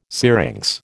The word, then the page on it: syrinx: American English